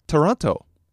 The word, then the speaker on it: トロント: トロント。